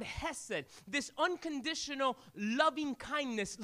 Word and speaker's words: hesed: The word mercy is the word in Hebrew, hesed. Can you say hesed this morning? If you're online, it's spelled H-E-S-E-D, H-E-S-E-D.